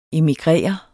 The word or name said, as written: emigrere